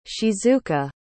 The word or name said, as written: shizuka